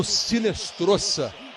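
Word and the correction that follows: Hinestroza: A pronúncia com R, né? Se escreve com H. Mas a pronúncia em espanhol é com R. Marino Rinestroça.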